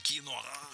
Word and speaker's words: киноа: А врач Гузмана объяснила, действительно ли киноа, вот это вот слово надо так произносить, киноа, да, полезнее, чем греча.